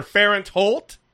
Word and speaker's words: Fahrenthold: I think that's how you say it. – Fahrenthold, yeah. I mean, it's either Fahrenthold or Fahrenthold, which is the way I say it.